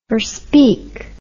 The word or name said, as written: forspeak